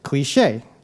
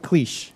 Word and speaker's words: cliché: So, for example, if I said it's a trite or overused expression, you would say cliché. The accent over the E tells me not to pronounce it cliche.